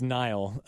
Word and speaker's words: Nail: From the St. Louis Blues, Mr. Nail Yakupov. – Nail? – It's Nile. but now I know how to spell it. – Damn. – The Nile. It's not just a river in Egypt. – N-A-I-L.